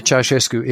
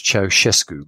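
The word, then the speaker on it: Ceaușescu: So in the Ceausescu era, and it is pronounced Ceausescu, by the way, not Ceausescu, as many people incorrectly That's write.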